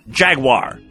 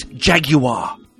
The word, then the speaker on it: Jaguar: Same with the car Jaguar. Oh, no. It's Jaguar.